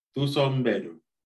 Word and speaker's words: Thuso Mbedu: Thuso Mbedu.